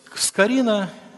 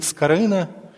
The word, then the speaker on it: Скорина: Франциск Скорина, или как на Украине говорит Скорина, его произносят через букву «ы», Франциск Лукич Скорина.